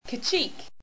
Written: kacheek

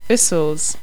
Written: usuls

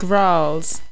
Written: grarrls

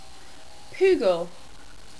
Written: Poogle

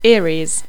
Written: eyries

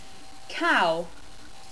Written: kau

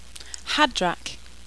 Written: hadrak

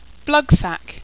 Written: blugthak

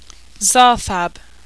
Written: xarthab

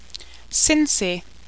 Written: Sinsi

